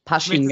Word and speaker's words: Pasching: Euer Spielort, das ist ja in Paschen, glaube ich, heißt oder Pasching. Pasching, genau. Falsch aussprechen, äh, ist ja quasi jetzt eure äh, Neuheimat, also oder seit ein paar Jahren eure Heimat.